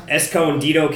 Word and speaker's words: Escondido: Stone Brewing has their Stone Brewing World Bistro and Gardens in Escondido, California.